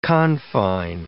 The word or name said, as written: confine